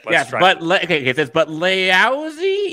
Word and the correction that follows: Laoise: equals Lee Shaw, which... How the hell does L-A-O-I-S-E pronounce Lee Shaw?